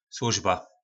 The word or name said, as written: slozba